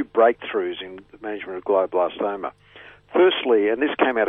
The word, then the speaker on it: glioblastoma: Today we're talking about, now, is it glioblastoma? Have I pronounced it right? – You have pronounced it absolutely correctly,